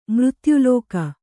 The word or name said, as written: ಮೃತ್ಯುಲೋಕ